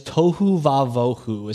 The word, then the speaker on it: tohu vavohu: It is tohu vavohu, is how you say it out loud.